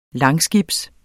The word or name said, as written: langskibs